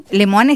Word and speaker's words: Lemoine: ¿No se llama Lemoine? – Lemoine está bien pronunciado.